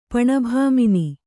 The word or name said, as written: ಪಣಭಾಮಿನಿ